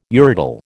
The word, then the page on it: urodele: American English